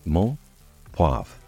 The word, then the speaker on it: menthe poivrée: Peppermint in French is pronounced menthe, poivre, and spelled menthe, M-E-N-T-H-E, poivre, P-O-I-V-R-E-E.